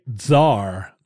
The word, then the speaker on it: Tsar: I'm going to say Tsar Obama. – You're not supposed to pronounce the T in Tsar. It is silent.